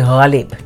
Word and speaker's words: Ghalib: It's Ghalib, not Ghalib, which is fine.